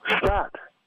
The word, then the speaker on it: Scott: Scott. – Scott? – Yes. – Spell it for me. – S-C-O-T-T.